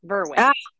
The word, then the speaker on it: Verwey: Very close. Verway.